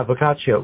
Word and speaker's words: Boccaccio: You know, explain how it's based on the Giovanni, how do you say it, Boccaccio? – Yeah, Boccaccio.